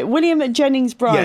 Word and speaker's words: William Jennings Bryan: So, yeah, Williams Jennings Bryan, I think I can't say his name. What is wrong with me today? William Jennings Bryan.